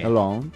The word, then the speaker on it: Lens: Long. – Porque la S al final tampoco se debería pronunciar. – El long.